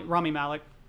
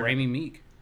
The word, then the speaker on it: Rami Malek: Rami Malek, that's his name, right? – Rami Malek. – Oh, is that how you say it? – Yeah. Rami Malek. I thought it was. Shit, I've been butchering. – Rami Meek. – I thought it was no, Rami Malek.